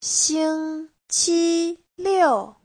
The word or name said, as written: xing1qi1liu4